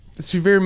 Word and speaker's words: Sevier: So, Sevier Middle School. That's where I went to middle school. It is still called that. But it's not spelled like severe weather. It's spelled like Sevierville, Tennessee.